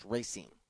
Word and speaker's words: Racine: So that, D-Cell, good for Mr. Carl Racine, I believe is how it's pronounced, Racine. – You are pronouncing it correctly.